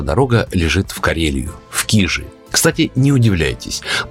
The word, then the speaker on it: Кижи: Сотрудники музея-заповедника произносят с ударением на первом слоге, но уточняют, что оба варианта правильные, потому что есть две версии происхождения слова «кижи».